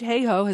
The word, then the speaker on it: Hayhoe: Kate Hayhoe has been our guest this afternoon in this portion of the program. Her last name is spelled H-E-Y, like hay, and then ho, like hoe your garden, H-O-E.